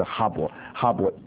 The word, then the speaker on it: Khyber: 好，那么所以这个，那么在底下就是给大家看那个巴基斯坦跟阿富汗大概这个情形啊，所以大概就是在，所以事实上呢，可以说就是在这个那隘口，就是哈勃隘口，大家记得一下，Khyber Pass，这个阿富汗这个 K 等于是巴拉发音的啊，所以应该就等于就是哈勃隘口，那么这个大家记得一下。